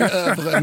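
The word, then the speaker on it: oeuvre: Ja, i den här podden säger vi övre, men vi säger det gärna med överdrivet och uselt franskt uttal.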